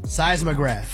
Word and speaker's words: seismograph: Seismograph.